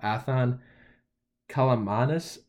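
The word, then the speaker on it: Athan Kaliakmanis: So you're going to have Athan Kalamanis, is how I think you say his name,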